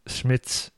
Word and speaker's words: Schmitz: So Jillian Schmitz.